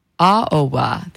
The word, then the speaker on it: Aoba: And you heard music from, let me make sure I say this right, Ichiko Aboa. Now, if I said it incorrectly, oh, I think I said it right, Aboa. Aoba, there we go.